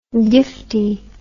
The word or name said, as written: giftie